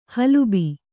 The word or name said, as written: ಹಲುಬು